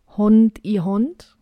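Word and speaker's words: Hånd i hånd: Okay, ich möchte mich gerne korrigieren. Das Lied heißt Hond i Hond. Wir haben es uns ja gerade angehört, deswegen wissen wir jetzt, wie es ausgesprochen wird.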